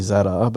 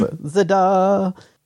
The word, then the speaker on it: Zadar: That's the way Kate pronounces. It's actually Zadar, but Kate's Zadar, Copa and Venice.